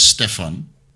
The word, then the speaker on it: Stefan: So my real name is Stefan. That's a mm. Dutch name. – And when I came to Thailand, nobody could pronounce that properly.